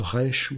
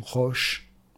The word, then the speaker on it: resh: Resh, aleph, shin, qui veut dire la tête, qui se prononce resh ou roche.